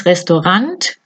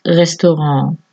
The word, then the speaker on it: Restaurant: No es restaurant con la T, porque viene del francés, lo pronunciamos restaurant.